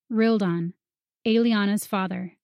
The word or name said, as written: Rildan